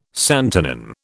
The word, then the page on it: santonin: British English